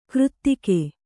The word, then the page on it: ಕೃತ್ತಿಕೆ: křttike